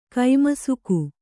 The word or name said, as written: ಕೈಮಸಕ